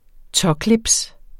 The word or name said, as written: tåclips